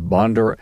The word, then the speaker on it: Bondurant: John Epstein, John's Automotive, Healthcare in San Diego, California, and president of ASCCA, and Williamson's Repair and Tire in Bondurant, Iowa.